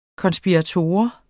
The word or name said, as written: konspirator